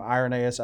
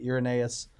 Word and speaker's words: Irenaeus: In the middle of the second century, you have guys like Justin Martyr, and some people pronounce his name Irenaeus, others say Irenaeus.